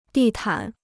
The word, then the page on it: 地毯: dì tǎn